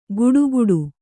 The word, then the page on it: ಗುಡುಗುಡು: guḍuguḍu